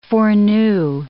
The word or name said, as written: foreknew